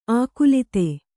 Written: ಆಕುಲಿತೆ

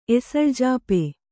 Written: ಎಸಳ್ಜಾಪೆ

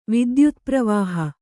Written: ವಿದ್ಯುತ್ಪ್ರವಾಹ

